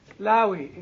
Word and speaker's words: Levi: Levi, is that right? – Levi. – Lawi in Hebrew. Lawi? Yes. Not Maui, Lawi.